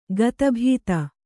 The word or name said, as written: ಗತಭೀತ